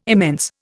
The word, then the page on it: immense: Indian